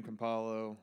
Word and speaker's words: Campolo: So I loved Tony Campolo. Did I pronounce that correctly? My hands are still tingling. My brain's a little. – Yeah, Tony Campolo, Philadelphia Bible teacher at Eastern University and Seminary.